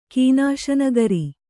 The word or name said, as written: ಕೀನಾಶನಗರಿ